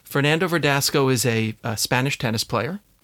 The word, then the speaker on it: Fernando Verdasco: Fernando Verdasco. Am I saying it correctly? – You're saying it correctly.